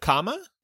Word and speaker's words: Kama: And I hope, am I saying it, Kuma? From I'm probably saying his name wrong. I'm pretty sure I'm saying it wrong. And Fantastic Beasts. – Yusuf Kama? – Yeah, there Kama. Ooh, I was like, what's that?